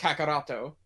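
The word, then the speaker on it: Kakaroto (カカロット): Kakaroto in Japan. – Yeah, so Vegito is because it's pronounced Kakaroto.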